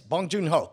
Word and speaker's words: Bong Joon Ho: Yeah, man, my pick is Boon Jong. How do you say his name? – Bong Joon Ho. – Yes, Bong Joon Ho.